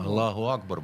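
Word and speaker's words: Аллаху Акбар (الله أكبر): Все, что э, связано, там тот же Аллах Акбар, что значит Аллах Велик, оно вызывает у нас, кроме страха, по-моему, даже и ничего. – Аллаху Акбар, во-первых, ты сказать, это произносится.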